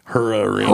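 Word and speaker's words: Hara Arena: Hair Arena. – You got to say it right, though. – R.I.P. – You got to say it with a U since you're from Dayton. Hur Arena.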